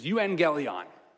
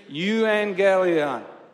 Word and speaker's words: euangelion: did you know that the word for good news in Greek is euangelion? Euangelion, it's spelled E-U. Can you say that with me on the count of three? One, two, three, euangelion.